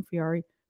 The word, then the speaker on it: Fieri: And they did all of these, like, apparently Guy Fieri, is that how you say his name? Fieri, yeah.